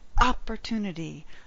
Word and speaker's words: opportunity: So there are times when you might stress, you must take advantage of this opportunity, but most of the time, opportunity, first syllable stress. So it's an interesting word that way, but always to, not chew.